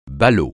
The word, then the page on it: ballot: francais